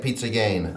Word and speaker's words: pizza giana: P-I-Z-Z-A, G-I-A-N-A. And then, again, we said it because it was Easter. How do you say that? – I say it pizza gain.